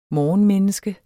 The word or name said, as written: morgenmenneske